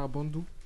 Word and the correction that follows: Abandé: Abandé. Abandé. Abandé.